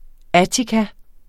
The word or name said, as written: attika